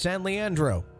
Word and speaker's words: San Leandro: San Leandro is pronounced San Leandro.